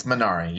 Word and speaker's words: Minari: Is it Minari? Minari? – It's Minari. Yeah.